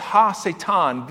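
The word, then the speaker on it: ha-Satan: And it's mostly pronounced Ha Satan, the adversary.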